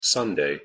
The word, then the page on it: sunday: English